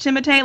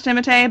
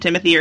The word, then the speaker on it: Timothée: It's pronounced Timothée. Like, yeah. It's supposed to be pronounced Timothée, but, like, most people just call him Timothy or Timmy.